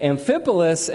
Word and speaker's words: Amphipolis: It says, Paul and Silas then traveled through the towns of Amphipolis and Apollonia, which I practiced pronouncing those several times. I'm feeling good that I nailed it. Amphipolis and Apollonia, and came to Thessalonica, where there was a Jewish synagogue.